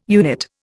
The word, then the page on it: unit: Indian